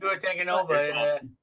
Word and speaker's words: Tagovailoa: I don't know how to say his last name. – Tua Tagovailoa. Yeah.